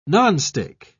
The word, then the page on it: nonstick: British English